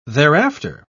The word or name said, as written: thereafter